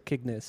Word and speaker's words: Cygnus: My favorite song is Cygnus. Is that how you pronounce it? Is it Cygnus or Cygnus? – Cygnus. Yeah, Cygnus.